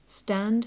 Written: stand

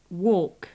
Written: walk

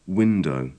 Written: window

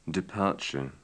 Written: departure

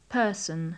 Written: person

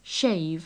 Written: shave

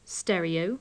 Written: stereo

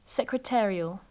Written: secretarial

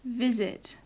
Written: visit

